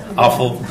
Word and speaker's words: offal: What's offal? You know what offal is. Awful. That's how you say it.